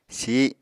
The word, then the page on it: sih: Apatani